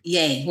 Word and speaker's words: Ye: Is it Ye or Ye? – Ye.